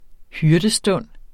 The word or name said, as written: hyrdestund